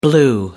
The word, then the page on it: blew: American English